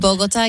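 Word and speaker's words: Bogotá: Is that how you say it? – Bogota, yeah. – So in Jersey, it's Bogota.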